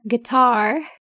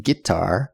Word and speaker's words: guitar: And uh, he loves to play his guitar, which is a guitar. – Guitar, yeah. The southern accent guitar, a little different stress on the first syllable.